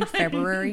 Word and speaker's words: February: Are you making fun of the way I say February? – No, I was making... – I pronounce both R's.